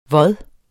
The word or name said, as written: vod